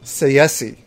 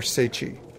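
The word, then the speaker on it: Tsaesci: Pronounced Seiyasi. That's how it's pronounced. Or Seichi. Seiyasi.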